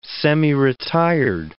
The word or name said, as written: semiretired